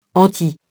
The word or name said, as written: anti-